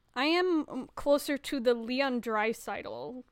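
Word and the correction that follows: Draisaitl: generation. Sorry, I said it wrong. You can correct me, Iris. I can see the pain in your face. – Ah, yes. Leon Dreiseitel generation.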